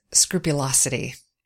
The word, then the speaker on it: scrupulosity: and that is scrupulosity. I'm saying it right, correct, Andy? – Yes.